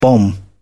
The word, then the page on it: bomb: British English